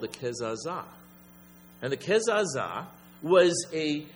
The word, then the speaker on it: Kezazah: And it's basically, it says here, he was a candidate for what's called a Kezazah. K-E-Z-A-Z-A-H.